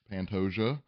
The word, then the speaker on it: Pantoja: We have our co-main event, Moreno and Pantoja, which is officially how you pronounce that, Pantoja.